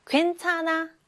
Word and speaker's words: 괜찮아: 괜찮아, 괜찮아. 자, 그러면 일단 괜찮아에서 괜은 그대로 소리가 나죠? 괜찮아, 괜 똑같아요. 그런데 차나에서 괜찮아, 찬아인가요? 아니면 차나인가요?